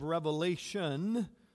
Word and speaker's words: Revelation: This morning, we are looking at God's Word found in the book of Revelation, without an S, as it's often mispronounced, the book of Revelation, chapter 2, verses 12 through 17.